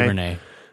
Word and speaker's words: DuVernay: I mean, I think Ava DuVernay, I can't say names. – DuVernay. – How do I say it? DuVernay. – DuVernay.